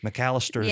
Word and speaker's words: McAllister: Did I say that right? – McAllister. – McAllister. – Yeah. – Like the deli. McAllister's yes. – Deli.